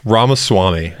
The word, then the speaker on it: Ramaswamy: V-I-V-E-K. – Vivek. – Yeah. – Pastrami? – Oh, shit. Ramaswamy. – Ramaswamy.